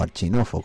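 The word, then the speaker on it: Arshinov: Yo qué sé, pues por ejemplo, el último libro que yo leí de la Biblioteca UCA, La historia de la Revolución Magnovista, de Piotr Arkhínov, o Archínov, como se pronuncia en ruso, o en ucraniano.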